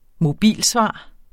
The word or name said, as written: mobilsvar